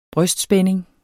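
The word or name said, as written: brystspænding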